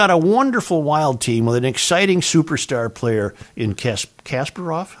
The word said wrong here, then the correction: Kaprizov: How do you say his name? – Kaprizov.